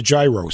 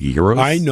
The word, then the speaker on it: gyros: I had a gyro sandwich. – Gyro. It would be pronounced gyros.